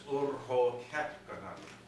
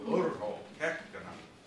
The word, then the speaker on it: Urho Kekkonen: If you just hit the first syllable hard of every word, it's an inflected language. You are speaking flawless Finnish. it'll be that easy for you. Urho Kekkonen.